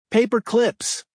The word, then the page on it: paper clips: American English